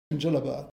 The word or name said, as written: djellaba